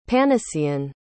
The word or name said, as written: panacean